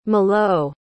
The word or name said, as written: milou